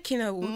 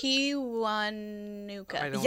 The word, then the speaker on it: Kiwanuka: Michael How do you say it? I don't want to say it bad. – Isn't it Kiwanuka? – Michael Kiwanuka. – Yeah, I was right.